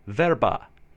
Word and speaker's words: verba: E is always to be pronounced E, and usually this is easy. But if you're not careful, words like lerfu, spelled L-E-R-F-U, meaning alphabetical character, verba, spelled V-E-R-B-A, meaning child, or kerlo, spelled K-E-R-L-O, meaning ear, sometimes sound like they are spelled lerfu, verba, curlo, with the sound.